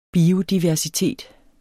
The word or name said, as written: biodiversitet